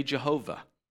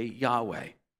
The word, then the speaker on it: Yahweh: And we don't know exactly how to pronounce that. Some people say Jehovah, some people say Yahweh. I usually say Yahweh.